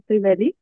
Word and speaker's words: Strivelli: We are with Rachel Strivelli.